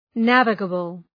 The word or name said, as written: navigable